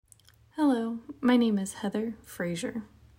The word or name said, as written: Heather Frazier